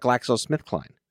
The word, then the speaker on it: GlaxoSmithKline: GlaxoSmithKline.